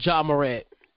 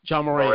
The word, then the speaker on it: Ja Morant: How you pronounce his name? Is it Monette? – Oh, Ja Morant. Ja Morant. – Morant, yeah. I